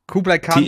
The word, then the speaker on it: Kublai Khan: Und das sind Kublai Khan, richtig ausgesprochen? – Kublai Khan, TX.